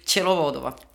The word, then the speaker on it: Ptšelovodova: ehk siis minu vastas istub Ülle Tšelovadova nii, Ülle, sa tegid sellist nägu, kuidas su perekonnanime tuleb äh, hääldada, palun? no nüüd ma olen juba selgeks õppinud, tere kõigile. mina olen siis Ülle ja perekonnanimi Tšelovodova.